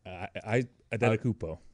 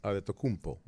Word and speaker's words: Adetokounmpo: Adetokounmpo. – Adetokounmpo.